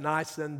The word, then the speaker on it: Nisan: On the 24th day of the first month, that would be Nisan that I spoke about, N-I-S-A-N. Some pronounce it Nisan, but it's, I understand, pronounced Nisan.